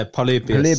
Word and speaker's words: Polybius: Is that how you say it? – Yeah, Polybius.